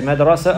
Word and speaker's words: madrasa: The word madrasa could be pronounced in more than one way. You can say madrasa or mudarrisa. Mudarrisa. Mudarrisa. So mudarrisa is a human being, is a person, is a woman, she's a teacher. But madrasa is a place, is a school.